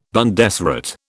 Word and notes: Bundesrat: British English

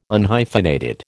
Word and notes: Unhyphenated: American English

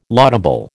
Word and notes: laudable: American English

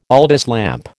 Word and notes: Aldis lamp: American English

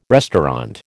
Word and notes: restaurant: American English

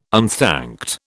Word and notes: unthanked: British English

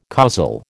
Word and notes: causal: American English

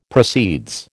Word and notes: proceeds: American English

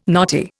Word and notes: knotty: Indian

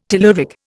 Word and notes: telluric: Indian

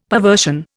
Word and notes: perversion: Indian

